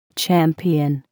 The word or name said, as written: champion